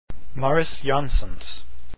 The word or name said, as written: jansons